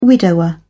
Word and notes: widower: British English

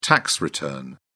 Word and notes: tax return: British English

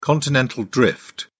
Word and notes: continental drift: British English

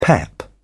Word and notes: pep: British English

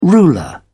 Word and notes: ruler: British English

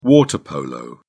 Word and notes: water polo: British English

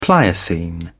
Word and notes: pliocene: British English

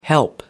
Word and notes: help: American English

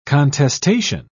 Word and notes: contestation: British English

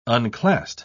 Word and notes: unclassed: British English

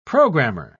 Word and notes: programer: British English

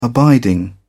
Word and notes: abiding: British English

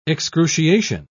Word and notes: excruciation: British English

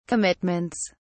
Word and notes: commitments: British English

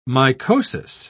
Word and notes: mycosis: British English

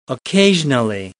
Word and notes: occasionally: American English

